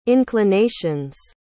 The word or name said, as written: inclinations